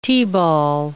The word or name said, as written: T-ball